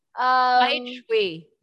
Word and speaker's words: Paeijwei: Paeijwei. Did I say it right? – Tama yeah, it's Pai. Like, Pai as in white.